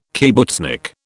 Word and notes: kibbutznik: British English

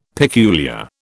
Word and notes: peculiar: British English

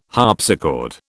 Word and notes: harpsichord: British English

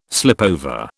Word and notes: slipover: British English

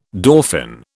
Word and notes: dauphin: British English

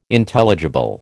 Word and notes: Intelligible: American English

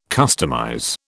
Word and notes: customize: British English

